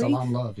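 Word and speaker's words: Salaam Love: Am I pronouncing that correctly? – Salam Love. Yeah.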